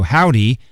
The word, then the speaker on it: HOVVDY: but it's spelled H-O-V-V-D-Y, kind of, if you get it, that V-V's like a W, Howdy, and the show is at the Chapel, Monday, October 19th at 8 o'clock, doors open at 7.